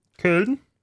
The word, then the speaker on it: Köln: Köln. – That's exactly how you pronounce it.